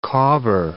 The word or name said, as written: cobber